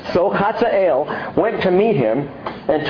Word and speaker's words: Hazael: So the king said to Hazael, and that's actually how you say it,